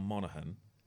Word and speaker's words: Monaghan: I know Dom Monaghan, I'm going to pronounce it right this time, Dom Monaghan,